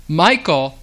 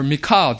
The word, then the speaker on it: Michal: And Michael, or Michal, depending on how you pronounce this name,